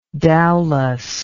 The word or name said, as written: dowlas